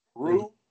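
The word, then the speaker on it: Roux: What's the name? Rue? – You can pronounce it R-O-U. It's kind of Creole-ish of sorts.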